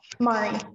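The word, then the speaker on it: Mairi: Mari, yes.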